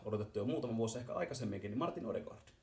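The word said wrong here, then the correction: Ødegaard: Äh, mun täytyy korjaa nyt, se, se lausutaan norjaksi Ödegaard.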